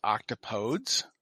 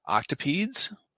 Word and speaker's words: octopodes: It's octopuses, octopi, and the word that I always said was octopodes, which is one of the accepted words, except that apparently I've been pronouncing it wrong. And they're saying the pronunciation more appropriately rhymes with the phrase "don't say please." So it would be octopedes.